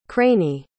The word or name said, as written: craney